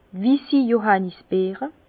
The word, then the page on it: wissi Johànnisbeere: pl.; Bas Rhin; Herrlisheim